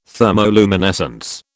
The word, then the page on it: thermoluminescence: British English